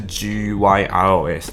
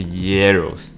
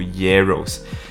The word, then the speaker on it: gyros: 它它的写它写的方法是 G Y R O S，但是它不是 Gyros，它是 Yeros。对我我故意去读查了一下到底怎么读，它是读 Yeros。